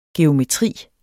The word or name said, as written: geometri